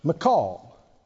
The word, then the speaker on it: Michal: 2 Samuel 6, David married Saul's daughter, whose name was Michal. I believe that's the correct pronunciation. I think I called it Michael for years, but Michal, I think it is.